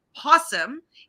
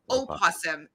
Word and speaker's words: opossum: Possum is the usual term in technical or scientific context. Opossum is preferred. Opossum can be pronounced with the first syllable or either voiced or silent.